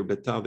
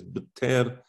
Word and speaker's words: Betar: He writes here, Beta, the, um, Later on, my father told me the pr- proper pronunciation is Betar, um, and so I follow the proper.